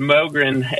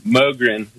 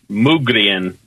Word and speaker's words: Mogren: It's Mogren. M-O-G-R-E-N. Mogren. It's a Swedish name. I guess the Swedes pronounce it Mugrien, but I'm one of those Americans, so we mispronounce everything. We call it Mogren.